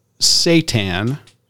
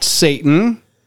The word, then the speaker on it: seitan: It's called S-E-I-T-A-N. That is the actual word for the type of protein pronounced Satan, not Satan. Not the Prince of Darkness.